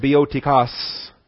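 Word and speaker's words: biotikos (βιωτικός): In the Greek, B-I-O-T-I-K-O-S, biotikos, B-I-O, that's the long O, the omega, T-I-K-O-S. In fact, biotikos, it looks like biotikos, only the omega is the, looks like a W, but otherwise it looks like standard English letters there, B-I-O, B-I-O-T-I-K-O-S, biotikos. That's the long O omega and the short O omicron.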